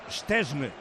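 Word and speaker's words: Szczęsny: En polaco, Szczesny.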